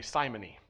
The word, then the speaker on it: simony: You could say simony, but it's simony is the way that it's pronounced.